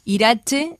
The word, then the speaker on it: Iratxe: Bien, así que reiteramos la dirección de correo electrónico, es irache, se escribe I-R-A-T-X-E, @ Beatokia. Beatokia se escribe B larga E-H-A-T-O-K-I-A punto info.